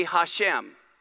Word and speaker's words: Hashem: Here is how you say Hashem. The H sound, the Shin, the Mem.